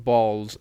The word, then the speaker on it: Ballz: It's called Balls, but it's spelled B-A-L-L-Z.